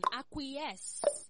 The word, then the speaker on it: acquiesce: A-C-Q-U-I-A-C-E. – Again? – A-C-Q-U-I-A-C-E. – Acquiesce. – A-C-Q-U-I-L-I-A-C-E. – If you can listen to my pronunciation, acquiesce. Listen to the ending, acquiesce. Your time is up. That spelling is wrong. The correct spelling is A-C-Q-U-I-E-S-C-E. A-C-Q-U-I-E-S-C-E.